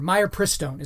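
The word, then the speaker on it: mifepristone: All right, the next thing we have here is that Planned Parenthood wants to remove warning labels from my uh, or myopristone is how it's pronounced, myopristone.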